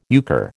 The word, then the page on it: euchre: American English